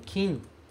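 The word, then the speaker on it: kin (金): Se a gente pronunciar esse kanji como kin, ele vai ser geralmente ouro. Certo? Eu vou tirar aqui de cima o dinheiro, porque eu vou colocar lá embaixo. Se ele for pronunciado kin, ele geralmente quer dizer ouro. Quando esse kanji aparece sozinho, quase sempre ele é pronunciado kin e ele significa ouro.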